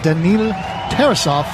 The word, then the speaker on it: Daniil Tarasov: Their backup goalie, Daniil Tarasov, if I'm pronouncing that correctly.